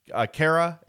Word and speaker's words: Khaira: So, Khaira is the right pronunciation.